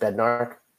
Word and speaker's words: Bednarik: Bednarik? Isn't it just Bednarik? Bednarik. I don't know, man. I'm not a pronunciation guy. B E D N A R I K. Oh, guess you're right.